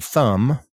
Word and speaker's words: thumb: So again, thumb, T-H-U-M-B, comb, which is es un peine, plumber, un plomero, and climb, o subir, trepar. So the B in these words is silent for some reason. And someone reading these words may say thumb or comb, and that's not what we say.